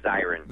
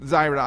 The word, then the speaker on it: Zirin: Welcome to the show, Dave Zirin. How are you doing today? – Hey, doing great. First, I should tell you it's Zirin. – Zirin, I'm terribly sorry.